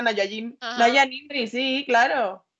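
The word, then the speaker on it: Najwa Nimri: Y además es que tenía un nombre muy raro, bueno, para mí era muy raro, era, que no sé cómo se pronuncia, me da un poco de coraje pronunciarlo mal, Nahua Nirmi. – Naya Nimri. – Naya. – Naya Nimri.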